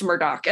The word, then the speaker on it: Murdaugh: But pronounced Murdoch.